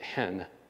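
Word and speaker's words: hen (ἐν): It's a two-letter word, en. They pronounce it hen. Hen.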